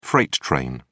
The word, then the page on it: freight train: British English